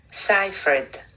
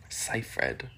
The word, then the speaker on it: Seyfried: Amanda Seyfried. – Seyfried.